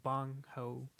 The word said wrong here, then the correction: Bong Joon-ho: The director? – Yeah. – Bong Joon-ho. – Bong Joon-ho, okay.